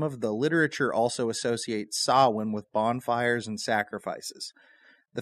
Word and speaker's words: Samhain: A lot of people will say that, but the word is actually Samhain.